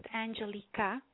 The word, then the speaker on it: Angelica: My name is spelled as Angelica, but it's actually pronounced Angelica.